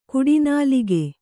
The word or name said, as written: ಕಡುನಾಲಿಗೆ